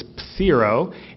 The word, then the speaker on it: pathero: And in Greek you pronounce the p, but it's pathero.